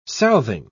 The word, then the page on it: southing: British English